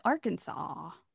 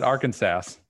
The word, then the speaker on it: Arkansas: Same with Arkansas. – Oh. – Yeah. – I always called it Arkansas. – Yeah. Well, that's wrong.